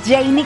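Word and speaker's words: Jeinny: arroba Janie Capistrán.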